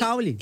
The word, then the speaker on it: Culkin: M-A-C-A-U. – Tá. – Macau. – Tá. L-A-Y. – L-A-Y. – Macaulay. – Macaulay. Macaulay. C-U-L-K-I-N. – Essa aí eu acertei. – Culkin. – O Culkin.